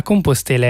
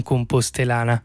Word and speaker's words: Compostela: La Compostela è, attenzione, non compostelana. Chi la pronuncia così rischia una maledizione fatta di sfortuna e vesciche.